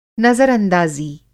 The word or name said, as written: नज़रअंदाज़ी